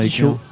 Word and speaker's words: Tycho: Donc, il y avait Tico. – Tico, c'est ça. C'est un DJ américain. Ticho. Ouais, qui s'écrit Ticho, T-Y-C-H-O.